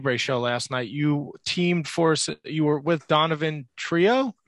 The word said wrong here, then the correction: Donovan Troy: Am I saying that right? – Donovan Troy, he was more managing Powder Baby, who was my partner.